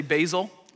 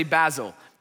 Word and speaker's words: Basil: Some people say Basil, like the leaf, okay? And then some say Basil. Basil sounds a little bit more academic, so I'm gonna roll with Basil today, okay?